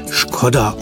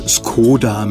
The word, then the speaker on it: Škoda: Demzufolge wird der Firmenname im Mutterland des Fahrzeugs Skoda ausgesprochen. Bei uns in Deutschland sagt man meistens Skoda mit langgezogenem O.